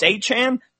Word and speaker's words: Achane: Achane, how do we say his name? We don't know. Nobody knows. Oh, it's Achane.